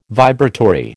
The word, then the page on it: vibratory: American English